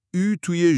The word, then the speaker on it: اوت: مثلا صدای بینیدار توی سپتامبر، نوامبر و دسامبر، یا اون صدای خاص او توی ژوئن و ژوئیه که باید با دهن گردتر گفته بشه. – آها. – و البته اوت که اون ت آخرش اصلا خونده نمیشه.